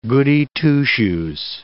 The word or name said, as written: Goody Two-shoes